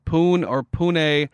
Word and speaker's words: Pune: We have a new listener from Pune, Maharashtra, India. – I'm sure that's exactly how you say it. – That's the correct pronunciation.